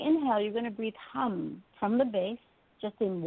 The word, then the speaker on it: ham: Now, inside that ball of light, you're going to imagine this sound, hum. It's spelled H-A-M, hum.